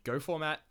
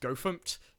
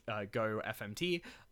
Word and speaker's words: gofmt: They have a tool called GoFormat, which is actually pronounced GoFMT, GoFMT.